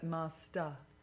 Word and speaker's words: master: Not er, but master. – Master. – No, ah. Master. – Master. – Master. – Master. – It's an ah sound at the end of master.